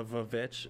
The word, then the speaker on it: VVitch: It is the VVitch, as in, because we use W's all the time now. But when they spelled it, it was two V's. Instead, and they would pronounce it as a W.